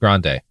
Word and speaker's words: Grande: He's probably hanging out with your favorite singer, Ariana Grande. – Sophie, it's pronounced Grande.